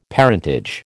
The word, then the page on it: parentage: American English